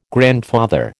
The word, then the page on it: grandfather: American English